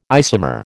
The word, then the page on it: isomer: American English